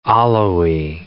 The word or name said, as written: Alawi